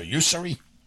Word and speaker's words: Ussery: Did I say that right? Ussery or Ussery? – Ussery.